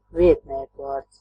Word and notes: pronom: France, Paris; [pʁo.nɔ̃]

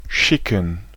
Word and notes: schicken: /ˈʃɪkŋ̩/; /ˈʃɪ.kən/

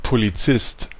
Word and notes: agent: [a.ʒɑ̃]; France

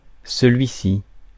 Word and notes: celui-ci: France; [sə.lɥi.si]

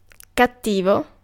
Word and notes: heavy: /ˈhɛv.i/

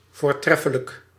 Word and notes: excellent: IPA: /ˈɛk.sə.lənt/; IPA: [ˈɛk.sə.lənt]; English

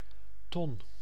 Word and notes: barrel: English

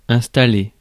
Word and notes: installer: France (Paris); [ɛ̃s.ta.le]

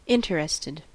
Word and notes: interested: American English; [ˈɪn.tə.ɹɪs.tɪd]

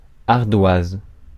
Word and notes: ardoise: France; [aʁ.dwaz]

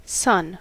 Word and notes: poika: [ˈpoikɑ]; /ˈpoj.kɑ/

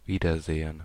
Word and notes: Wiedersehen: [ˈviːdɐˌzeːən]

